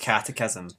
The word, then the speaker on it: catechism: Firstly, it's pronounced catechism, but also catechism. It does not look like that.